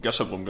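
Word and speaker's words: Gasherbrum: Das ist am Gashabrum. Richtig? Habe ich das richtig ausgesprochen? – Gashabrum, genau.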